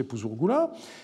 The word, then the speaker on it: Puzurgula: les documents remontent au grand-père qui s'appelait Puzurgula ou Puzurnin c'est euh, écrit de deux façons, mais on peut penser que son nom était prononcé Puzurgula.